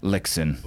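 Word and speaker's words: Lyxzén: Yeah, and if you, you know, if he were to say it, it would have a little bit of a Swedish flair on there, like Lixon or something. But Lixon is how you know, us Anglophiles, pronounce it.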